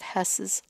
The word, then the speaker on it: Hoess: He told the story of Rudolf Hess, not Hess, H-E-S-S, that Rudolf Hess, who also was a henchman for Hitler, but Rudolf, H-O-E-S-S, pronounced the same.